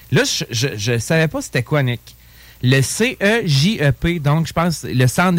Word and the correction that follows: Cégep: Je lisais ça, je l'ai prononcé. – Cégep. – C'est le Cégep, manifestement. – C'est un G? – Oui, c'est un G, okay.